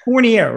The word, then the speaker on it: Fournier: Camille, I even forgot to ask you, even when we were talking before, it's Fournier, right? Am I pronouncing it? – Fournier, actually. – Okay. – The A is the only French part we've retained, but Fournier, yeah.